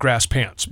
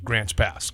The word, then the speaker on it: Grants Pass: Well, if you live there, you call it Grass Pants. But if you don't live there, you say it correctly, Grants Pass.